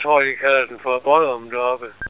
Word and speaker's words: Boddum: Det er en by, der hedder Bodum. Sådan tror jeg, det udtales. B-O-D-D-U-M. Bodum.